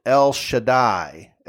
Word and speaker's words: El Shaddai: Now, the word Almighty in Hebrew is pronounced El Shaddai, El Shaddai.